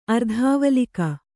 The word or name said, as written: ಅರ್ಧಾವಲೀಕ